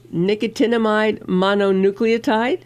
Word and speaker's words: nicotinamide mononucleotide: And one of the things on his list, and this is a fancy word, nicotinamide mononucleotide.